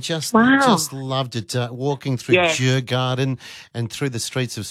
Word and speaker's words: Djurgården: Now, that would be Djurgården, by the way. I'm just going to give you a bit of a pronunciation correction. Djurgården.